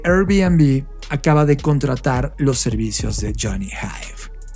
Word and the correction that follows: Ive: Y perdón por pronunciar mal Hive, es Ive Gracias, Fer.